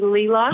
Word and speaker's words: Leela: It's actually Leela.